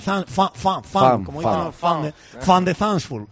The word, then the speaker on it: van de Zandschulp: Pues mira, ahora cuando estamos, cómo se pronunciaba Soyuncu o Soyuncu o lo que sea del Atleti, pues lo mismo, entonces el propio jugador diciendo cómo se pronuncia y se dice Botic van de Zandschulp. – Van de Zandschulp. Bueno, fan de fan de Zandschulp.